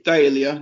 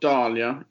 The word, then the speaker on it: Dahlia: It's Dahlia, not Dahlia, in my opinion.